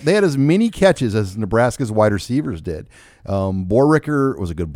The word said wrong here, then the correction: Borkircher: blocker, and did I say his name right? – Borkircher. – I always want to say Borricker.